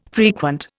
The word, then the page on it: frequent: Indian